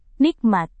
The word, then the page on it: nikmat: Indonesian